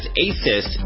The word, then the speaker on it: ASUS: While most people pronounce the company as ASUS, it's really ASUS, A-S-U-S, a hugely popular tech brand that makes a number of award-winning products.